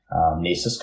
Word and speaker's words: NAICS: For people who don't know, you can easily find that it's spelled NAICS.